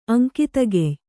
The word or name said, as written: ಅಂಕಿತಗೆಯ್